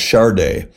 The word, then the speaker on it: Sade: Now, when you first heard of her, probably back in the 80s, you probably didn't know, like myself, how to pronounce her name. It's spelled S-A-D-E, pronounced Chardé.